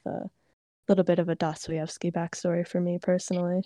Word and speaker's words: Dostoevsky: It's just Dostoevsky.